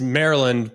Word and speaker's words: Maryland: You say it's Maryland, but it's like Merlin, the wizard. That's how you got to say it.